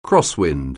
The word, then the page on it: crosswind: anglais